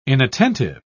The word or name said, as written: inattentive